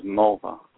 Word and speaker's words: Mulva: Rhymes with a female body part. – Yeah. – Mulva? – Yeah.